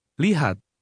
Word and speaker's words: lihat: Lihat. Lihat. – Like the K, a T at the end of a word is silent.